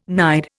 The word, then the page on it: nide: Indian